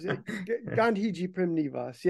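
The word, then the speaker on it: Gandhiji Prem Niwas: I think it's Ganjipram Nivas. Is that even close? – Oh yeah, man, it's close. Gandhiji Primnivas. Yeah, I mean, it means Gandhi's new life.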